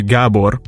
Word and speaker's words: Gabor: It's actually Gabor, but it's quite difficult for many people to pronounce, especially French, so I just roll with Gabe, usually. – Okay, wait, Gabor, like that? – Yeah, exactly.